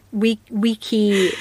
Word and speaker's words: vici: So that wiki is the C, what we think of as C being pronounced as K, as in the word for cheese.